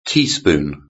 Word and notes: teaspoon: British English

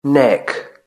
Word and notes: neck: British English

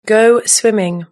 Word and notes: go swimming: British English

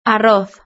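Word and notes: arroz: Spanish (Spain)

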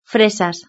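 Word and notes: fresas: Spanish (Spain)

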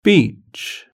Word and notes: beach: British English

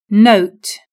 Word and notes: note: British English

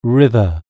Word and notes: river: British English

